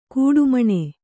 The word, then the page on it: ಕಡೆಮಿಣಿ: kaḍemiṇi